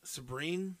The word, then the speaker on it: Sabryn: But her name is, I don't know how to pronounce this, Sabrine Rock, S-A-B-R-Y-N. Sabrine? – Yeah, that sounds about right.